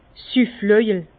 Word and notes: Süfflöjel: Bas-Rhin; Herrlisheim

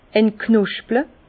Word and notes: entknosple: Bas-Rhin; Herrlisheim

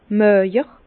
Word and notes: màjer: Bas-Rhin; Herrlisheim

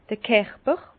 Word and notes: d'r Kerper: Bas-Rhin; Herrlisheim

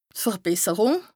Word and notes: d' Verbesserùng: Bas-Rhin; Strasbourg

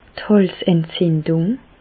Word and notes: d' Hàlsentzindung: Bas-Rhin; Reichshoffen

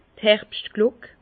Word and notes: d' Herbschtglock: Bas Rhin; Herrlisheim

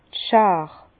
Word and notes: d' Schaar: Bas-Rhin; Herrlisheim

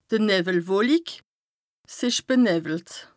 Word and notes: d' Nëwwelwolik, 's ìsch benëwwelt: Bas-Rhin; Strasbourg

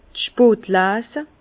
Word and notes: d' Spotlese: Bas-Rhin; Herrlisheim

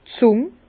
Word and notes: d' Zùng: Bas-Rhin; Herrlisheim

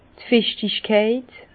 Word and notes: d' Fichtigkeit: Bas-Rhin; Herrlisheim

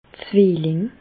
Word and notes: d' Zwilling: Bas-Rhin; Reichshoffen

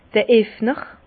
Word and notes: d'r Effner: Bas Rhin; Herrlisheim

